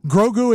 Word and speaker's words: Grogu: Grogu is Baby Yoda that you see. Yeah. Yeah, exactly. – Grogu. I am Grogu.